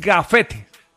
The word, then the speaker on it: gafete: No, porque no es grafetes. – Ah, ok. – Es gafete.